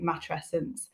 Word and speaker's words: matrescence: Yes, you have said it properly. I pronounce it matrescence.